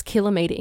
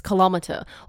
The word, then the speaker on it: kilometre: And then I'd cop a text message from our boss saying, oh, you pronounce kilometre incorrectly. It's kilometre.